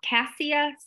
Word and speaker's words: cassia: I think it's pronounced cassia, C-A-S-S-I-A. I'm sorry if I mispronounced. – That's how I would have pronounced it.